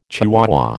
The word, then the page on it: chihuahua: American English